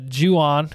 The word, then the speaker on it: Ju-on: After watching the Ju-on, correct? Am I saying that correctly? – Yeah, yeah.